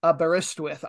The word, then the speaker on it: Aberystwyth: And that one looks like, to me, it should have its emphasis on the second syllable. And in fact, it's the third one, but we'll get into that a little bit later. Aberystwyth. I still want to do it wrong. Aberystwyth. Anorthosis.